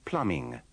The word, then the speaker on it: plumbing: In these sentences, you will have to be careful about the pronunciation of plumber and plumbing. Remember, the B is silent in both words.